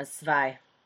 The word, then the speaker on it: zwei: Zwei.